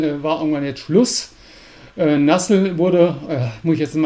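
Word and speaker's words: Nuzzel: Äh, Anlass war, äh, der wirkliche Anlass war die, die, die, Einstellung von Nassel oder Nutzel oder ich glaube, Nassel ist äh, die korrekte Aussprache im Englischen, ja.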